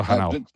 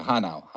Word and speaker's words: Hanau: He's been DJing for 35 plus years, I believe, from Frankfurt am Main, now living in Hanoi. I think I said that right. Well, Hanau. – That's close, yeah. Hanau.